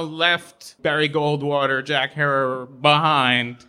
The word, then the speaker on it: Herer: and the superhero we know as Jack Herer rhymes with terror is born right there.